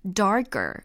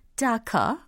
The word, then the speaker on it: darker: darker 이렇게 발음하셔도 되고 아니면 영국식 영어를 선호하신다면 darker 이렇게도 연습하시면 재밌을 것 같아요.